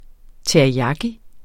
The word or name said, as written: teriyaki